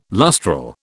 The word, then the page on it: lustral: British English